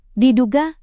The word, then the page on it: diduga: indonesian